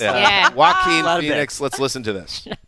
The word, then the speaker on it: Joaquin: and Jacqueline. Is that how you pronounce it? – Joaquin Phoenix.